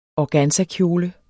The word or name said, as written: organzakjole